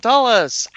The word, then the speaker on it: Tullis: And here we are, joined by the fat dragon himself, Tom Tullis.